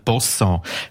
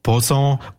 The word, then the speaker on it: Bosson: Und die Namen Baschung, Baschung und Boschung sind eingedeutschte Formen vom Familiennamen Boschon oder eher vom Familiennamen Bosson. Der wiederum geht auf einen entsprechenden Flurnamen zurück, der in der Welschweiz verbreitet ist und der eben als Bosson, Boson oder im Patois als Boschon ausgesprochen wird.